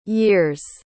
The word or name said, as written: years